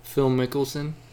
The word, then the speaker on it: Phil Mickelson: Well, Phil Mickelson? – Yeah.